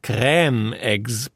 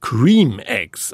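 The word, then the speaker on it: Creme Eggs: Nachdem die Herstellerfirma Cadbury in einem Tweet die französisch anmutende Aussprache Crème Eggs propagierte, brach ein Shitstorm los, der das Unternehmen bald darauf veranlasste, den ersten Tweet zu löschen und umgehend zu korrigieren, dass die Eier selbstverständlich Cream Eggs ausgesprochen würden.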